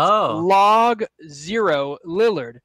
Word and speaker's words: Logo Lillard: Well, it's spelled Logo Lillard, but it's Log Zero Lillard.